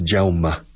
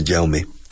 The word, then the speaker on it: Jaume: Because if from Barcelona it's Jaume, the pronunciation, but from Valencia, Jaume.